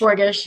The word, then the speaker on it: Luxembourgish: Am I saying that right? Burgish or Borgish? Burgish. – Borgish, yeah.